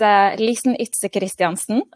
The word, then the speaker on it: Lisen Ydse Christiansen: My full name is, if I'm going to pronounce it in my native tongue, it's Lisen Ydse Christiansen, but Lisen.